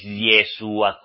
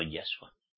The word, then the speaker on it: Yahshua: No es Jehová. No es Jesús. No es Yeshua como muchos de ustedes lo pronuncian, sino Yahshua.